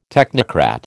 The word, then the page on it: technocrat: American English